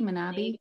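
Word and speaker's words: Manebi: Manabi.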